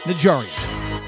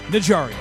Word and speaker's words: Najarian: On today's episode of the Thrive Time Show, we look to explain the mystery, the myth, and the legend of Berj Najarian, the New England Patriots' secret weapon that creates the space for Bill Belichick to work and win.